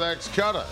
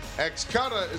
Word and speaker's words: XCUTTA: There is a new sports betting market in town, and it's called XCUTA. It's spelled X-C-U-T-T-A. XCUTA is a sports count cutter, combining the adrenaline of live auctions and the strategy of sports prognostication.